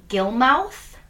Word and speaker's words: Gilmouth: She began a pen pal relationship with 77-year-old Everson Gilmouth, literally how it's spelled.